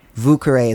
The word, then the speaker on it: Vieux-Carré: It's one of those sort of like local pronunciations or Americanized pronunciations, whereas in relation to the cocktail, Vieux-Carré is what you'll hear in a bar. It's what you'll hear people call it.